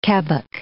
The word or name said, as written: kebbuck